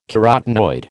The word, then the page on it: carotenoid: American English